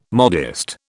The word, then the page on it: modest: British English